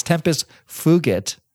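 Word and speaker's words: tempus fugit: You pronounce it correctly. It's tempest fugit. If you took Latin. – Yeah, you say your G hard.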